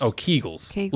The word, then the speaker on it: Kegels: Oh, kegels. Like eagles.